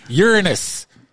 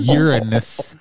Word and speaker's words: Uranus: Uranus. I'm sorry, I mispronounced it. Uranus.